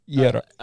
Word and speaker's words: Jira: Can you say it properly? Just Jira. – As in the Finnish.